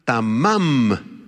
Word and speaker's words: tamam: La palabra hebrea tamam, con acento en la segunda A, T-A-M-A-M,